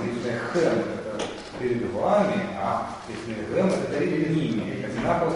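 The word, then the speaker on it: Лифнехэм (לפניהם): Лиф-не-хэм и лиф-не-гэм. – Лиф-не-хэм и лиф-не-гэм. – Да. Во втором и в третьем со множеством числа будет лиф-не.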